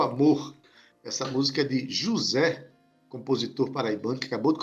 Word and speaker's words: Juze: Esse rei é com H-E-Y. E o artista se chama José, se escreve J-U-Z-E.